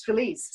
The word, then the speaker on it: Filiz: Well, I think, obviously going back, here I am, my name's Filiz, so it's spelled F-I-L-I-Z.